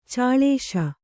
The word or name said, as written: ಚಾಳೇಶ